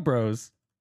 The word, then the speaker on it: Breaux: The Bro Bros. – Oh, yeah. Bro Brothers. – Spelled B-R-E-A-U-X.